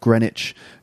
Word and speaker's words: Greenwich: Greenwich, it's actually pronounced Greenwich.